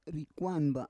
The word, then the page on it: rikwan ba: Roon